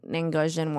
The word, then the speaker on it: Angosian: So, like, it shouldn't, like a one man shouldn't just technologically speaking, they're way more advanced than whatever bullshit shuttlecraft this Anjoran. – Yes. – Did I say it right? – Angosian. Three. I'm an Angosian three.